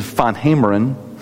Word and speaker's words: van Gameren: One of my Old Testament professors, his name was Dr. Willem van Gameren. That's the way we, of course, the way we would pronounce it. But I think the Dutch pronunciation is van Hameren.